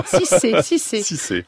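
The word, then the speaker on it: Sidse: Irène Frachon avec la sortie de La fille de Brest, donc demain, ce film d'Emmanuel Berco, avec Sitze Babette Knudsen, est-ce que je prononce bien son nom ? – Sûrement, Sitze.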